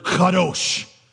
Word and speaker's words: kadosh: The word in Hebrew that we translate as holy is the word kadosh.